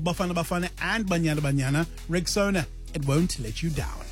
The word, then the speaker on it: Rexona: It's pronounced Rexona, simply strong and easy to remember.